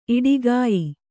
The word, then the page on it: ಈಡುಗಾಯಿ: Kannada